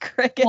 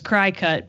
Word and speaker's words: Cricket: And I guess Jerry pointed it out to me that the logo has antennas, so it obviously is pronounced cricket.